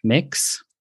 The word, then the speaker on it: Mx: And we didn't have a gender neutral prefix until more recently, which is MX, which is pronounced like mix. So you'd say like mix Murphy.